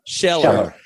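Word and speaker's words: Scheler: And then that concept, then there's the resentment part that comes from Scherer, or I don't, how do you say his name? Scherer? – Scheller.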